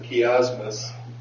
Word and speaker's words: chiasmus: It's a Greek word, and I believe the CH sound is pronounced as a hard sound, so I'm going to pronounce it chiasmus.